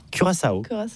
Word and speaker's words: Curaçao: Donc comment on prononce Curaçao? – Curaçao.